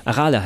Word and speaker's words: Arale: Was mich bei der deutschen Anime-Serie gestört hat, ist wieder dieses typische, wir müssen jeden Namen auf der letzten Silbe betonen. – Arale. – Hallo Arale. Arale heißt das, verdammt.